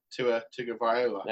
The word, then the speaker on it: Tagovailoa: What, Tua Tagovailoa? – And you've done it again.